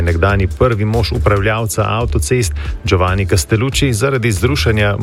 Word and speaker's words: Giovanni Castellucci: Čestitke za izgovorjavo. Giovanni Castellucci. Bravo. Lepo.